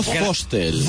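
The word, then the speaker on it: Vostell: La fonética alemana marca que la V se pronuncia como F. – Vale. – y es Museo Fostel.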